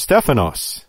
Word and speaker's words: Stephanos: But that's a modern convention, and there's no doubt but that in Greek, it was Stephanos. And you pronounce it like an F, not a V.